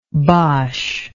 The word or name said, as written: boche